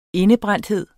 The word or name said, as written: indebrændthed